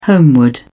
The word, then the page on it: homeward: British English